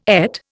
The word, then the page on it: ait: Indian